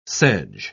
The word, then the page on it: sedge: British English